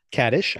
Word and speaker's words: Kaddish: Kaddish, I think you were correct about that pronunciation.